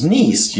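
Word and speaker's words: Nissi: So I'm going with the way the guy pronounced it, which was Nisi, Yahweh Nisi.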